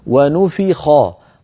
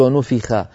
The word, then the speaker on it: ونفخ: ونفخ خاء، هكذا تنطق العرب يا أخي سينان حرف الخاء، خاء خاء، ولا تقول العرب خاء خاء، هذه خاء ليست عربية، خاء. ونفخ ونفخ، الخاء مفخمة، لا تقول ونفخ.